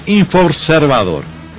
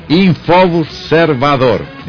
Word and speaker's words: InfoObservador: Y su página, digo, ya que hablamos de Google, en Google, en el buscador en Google, ponen InfoObservador, o sea, para que quede clarito, es INF, con la palabra observador pegado a la INF, InfoObservador.